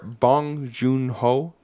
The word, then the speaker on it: Bong Joon-ho: Hot off of winning every single Academy Award and making history as the first foreign language film to win Best Picture, director Bong Joon-ho.